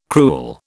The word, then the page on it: crewel: British English